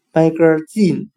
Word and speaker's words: magazine: 我们用谐音法来记这个单词 magazine，它的发音很像汉语的卖个劲，卖东西的卖，一个两个的个，劲是尽力。